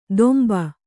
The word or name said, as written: ದೊಂಬ